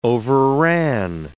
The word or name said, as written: overran